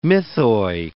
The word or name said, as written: mythoi